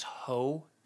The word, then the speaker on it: Heaux: So I talked about this earlier, Jasmine Sullivan's Ho Tales, but it's spelled H-E-A-U-X.